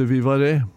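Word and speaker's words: Vivarais: Ja, att nej men du lager den där surdeigsbrödet som heter vivare. – Vivare? – Ja. – Ja vel. – Skrives Viva Reis. – Ja, skjønner. – Men du uttaler det Viva Re, for det fransk, og det har opprinnelse fra nede I Ardèche-området I Frankrike, så vidt jeg husker.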